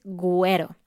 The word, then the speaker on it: güero: Se escribe con la letra G, U, E, R, O y la U tiene dos puntos sobre la U, que es una diéresis, güero.